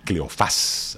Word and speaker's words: Cleofás: Desde aquí saludamos, abrazamos y felicitamos a las personas que llevan los nombres de Aurelia y Cleofás, así con acento en la Cleofás.